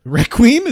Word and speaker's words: Requiem: And of course, right now we are playing Plague Tale Requiem. – Requiem? Is that how you pronounce it? – Yes, I have decreed that is.